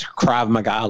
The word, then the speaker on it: Krav Maga: Krav Maga. – She does that on. They used to advertise that in the Village Voice. Krav Maga lessons.